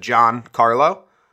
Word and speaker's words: Giancarlo: I just said Giancarlo. Say just Giancarlo.